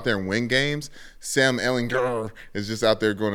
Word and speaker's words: Ehlinger: Hard G. It's Ehlinger.